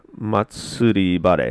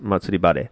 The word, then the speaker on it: Matsuribare: Matsuribare. It looks like masturbation, but it actually says Matsuribare, and is seeking government approval to begin cultivation in Japan.